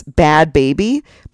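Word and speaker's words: Bhad Bhabie: So I YouTube it and it's Bad Baby, but it's spelled B-H-A-D and then baby is B-H-A-B-I-E.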